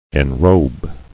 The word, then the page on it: Enrobe: en·robe